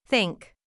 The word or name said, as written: think